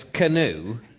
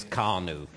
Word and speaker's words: canoe: Well, it was canoe, which you previously spent much of an episode of this programme pronouncing as carnoo.